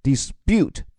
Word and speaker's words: dispute: 让关于这个词的发音也给参加晨读的小伙伴支个招，就是你的重音既可以放在 dispute 第二个音节上，也可以放在最前面 dispute 都可以。